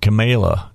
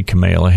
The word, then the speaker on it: Kamala: Senator Kamala Harris, am I saying that right? Because she said Kamala is what she wants to be called. So I'll say Kamala Harris of California,